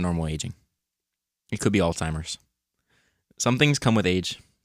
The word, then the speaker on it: Alzheimer's: It also says pronunciation note, Alzheimer's. Alzheimer's. In case you forgot.